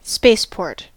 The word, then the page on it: spaceport: American English